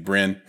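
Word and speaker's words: Brenne: I've got some Bryn as well, which is like a French import that I believe in and that I mispronounced for 2 years as Brenet because it's French. It's actually Bryn.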